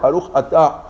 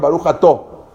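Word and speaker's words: Baruch Atá (בָּרוּךְ אַתָּה): En verdad se decía la letra A o sea, no era ni una O ni una A. Baruch Atá. Pero los sefaradí no sabían pronunciarla, entonces dijeron Baruch ta. Los ashkenazim tampoco sabían pronunciarla, entonces dijeron Baruch Ató.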